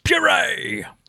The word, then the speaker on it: Bure: Vlad Bure." And I was like, yes, Bure.